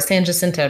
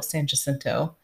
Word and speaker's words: San Jacinto: San Jacinto, he pronounced it as the Battle of San Jacinto. I mean, he's not wrong, but nobody pronounces it that way, you know.